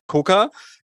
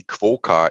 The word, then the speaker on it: Coca: Es ist doch einfacher, als man denkt. Es ist dann doch einfach nur der Coca. Ja, aber bestand der Name seit Anfang oder hatte der vielleicht vorher einen anderen Namen? Wann kam dieser Name dazu? So ein Stück weit vielleicht so die Geschichte des Namens, wie ist dieser Name entstanden? – Sprichst du jetzt an Aussprachen wie Quoka etc.? – Genau.